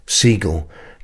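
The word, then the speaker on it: Segall: It's pronounced like the bird, Siegel.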